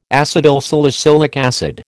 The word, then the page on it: acetylsalicylic acid: American English